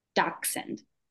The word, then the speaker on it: dachshund: That's a dachshund, right? – It's pronounced dachshund.